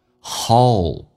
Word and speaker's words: hold: Hold 指的是拥抱的意思，但是这个单词很难发，首先发双元音 O，先发 A 再发 U O，但是 L 这个发音舌尖抵住你的上齿龈，在牙齿的后背一点地方叫 O Hold And holds me, and holds me like a, like a, 有个连叫 like a baby when I'm hurt.